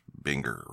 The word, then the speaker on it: Buenger: It is spelled B-U-E-N-G-E-R, but it is not pronounced Bunker. It is Binger or something along those lines.